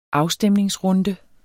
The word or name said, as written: afstemningsrunde